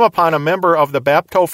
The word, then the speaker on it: bapto: First of all, the parent word, bapto, that I've mentioned multiple times. That is the basic verb, bapto, and that is spelled B-A-P-T-O. Related to bapto is the noun Baptisma. Spelled B-A-P-T-I-S-M-A, baptisma.